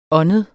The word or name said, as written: åndet